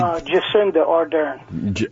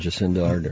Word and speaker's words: Jacinda Ardern: Jacinda Ardern. – That's the one, Jacinda Ardern.